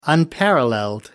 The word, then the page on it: unparalleled: American English